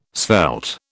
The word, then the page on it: svelte: British English